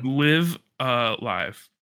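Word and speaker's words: Live A Live: Live Alive.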